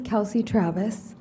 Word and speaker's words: Kelcy Travis: My name is Kelcy Travis, K-E-L-C-Y-T-R-A-V-I-S.